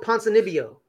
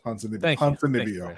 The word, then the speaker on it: Ponzinibbio: Santiago Ponzinibbio. – Thank you, Ponzinibbio.